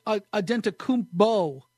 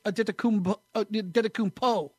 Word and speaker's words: Adetokounmpo: Everybody says Adetokounmpo, using the P because that's the way it is.